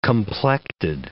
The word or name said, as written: complected